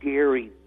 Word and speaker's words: Gerry: Most people say Jerry, but Elbridge Gerry did pronounce his name with a hard G, so I'm a hard, you know, hard and fast gerrymanderer.